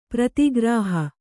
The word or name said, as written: ಪ್ರತಿಗ್ರಹ